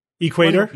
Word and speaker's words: equator: It's equator. What are you guys talking about? You had the accent on the wrong syllable.